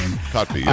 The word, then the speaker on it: Kotb: She said that, and I guess she was getting interviewed by Hoda Kotb, or however you say her name. – Kotb, yeah.